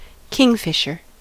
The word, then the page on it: kingfisher: /ˈkɪŋfɪʃə/